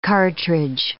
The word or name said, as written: cartridge